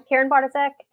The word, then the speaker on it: Karen Bartoszek: So I'm Karen Bartasek, and you got the name right.